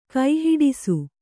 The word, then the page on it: ಕೈಹಿಡಿಸು: Kannada